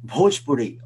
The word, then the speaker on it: Bhojpuri: Okay, um, uh, the word Bhojpuri, for example, was mispronounced. So it's Bhopa. It's a heavy H there, and it's not Bhojpuri. It's Bho, Bho, Bhojpuri.